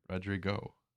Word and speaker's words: Rodrigo: He would kill for Olivia Rodriguez. – What'd you say? – Olivia Rodriguez. – It's not her name. – What's her name? – Rodrigo.